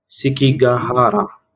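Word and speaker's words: Sekigahara: اسمها سيجيكارا، سيجيكارا، سيكيغاهارا. – هدي لي إياها. – S E K. – S E K. – I G. – سيكيغاهارا.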